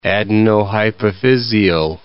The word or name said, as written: adenohypophysial